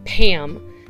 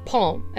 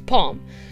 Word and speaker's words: Pom: Several people keep calling her Pam. It's like, no, it's Palm. As like pom-pom. She's French for crying out loud. It's Palm.